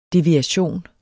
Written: deviation

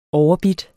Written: overbidt

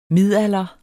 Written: midalder

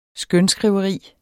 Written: skønskriveri

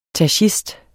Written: tachist

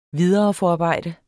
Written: videreforarbejde

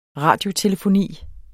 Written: RADIOTELEFONI

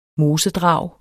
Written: mosedrag

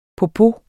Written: popo

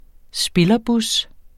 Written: spillerbus

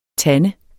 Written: TANDEN

